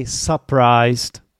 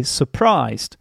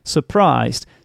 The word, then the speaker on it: surprised: So you don't say surprised or something like that. You should say surprised. Surprised. Stress means that the syllable is louder, stronger, more prominent. And the unstressed syllable, on the other hand, that would be the s, the one that contains the schwa sound, is weaker, sounds weaker. So it should be surprised.